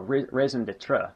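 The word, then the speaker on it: raison d'être: A major raison d'etre for our ruinous fight, oh no, raison d'etre, that's how it's pronounced, I forgot about that.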